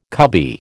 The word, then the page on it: cubby: American English